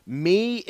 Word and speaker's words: Mie: They were called Me and K. It was spelled M-I-E and K-E-I, but you pronounced it Me and K.